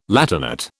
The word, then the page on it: Latinate: British English